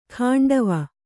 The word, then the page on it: ಖಾಂಡವ: kannada